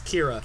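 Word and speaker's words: Qi'ra: It's Kira. – Kira. – Like Knightley, except there's a Q for some reason.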